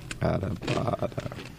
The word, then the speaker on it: adobada: Adobada.